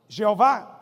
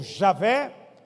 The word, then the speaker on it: Jeová: E esse nome, Eu Sou, que era, Eu Sou o que é, Eu Sou o que há de vir, em hebraico são quatro letras, YHWH, é o impronunciável. Mas aqueles linguistas, né, que entendem de pronúncia, dizem que uma pronúncia possível é Jeová, que é muito usada, ou Javé, ou Yahvé. Você pode falar qualquer um dos três.